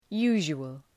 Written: usual

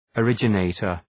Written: originator

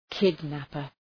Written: kidnapper

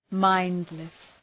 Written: mindless

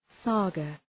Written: saga

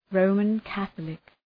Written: Roman Catholic

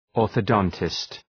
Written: orthodontist

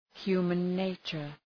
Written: human nature

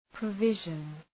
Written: provision

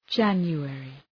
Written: January

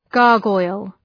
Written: gargoyle

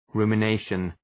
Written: rumination

